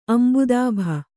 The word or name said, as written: ಅಂಬುದಾಭ